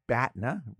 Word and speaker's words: BATNA: How do you pronounce that? – BATNA rhymes with CATNA.